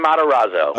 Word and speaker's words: Matarazzo: Gaten Matarazzo.